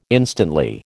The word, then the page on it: instantly: American English